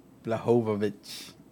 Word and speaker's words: Błachowicz: Blachowicz. – The L is silent, but sometimes we pronounce it anyway.